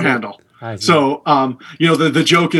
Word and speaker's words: Handel: Handel, is that how you say it? Handel scored our lives as well.